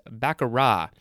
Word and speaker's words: baccarat: There's no T, actually, so it's baccarat, B-A-C-C-A-R-A-T. It's spelled with a T, but the T is not pronounced.